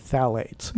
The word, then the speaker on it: phthalates: but these are endocrine-disrupting chemicals called phthalates.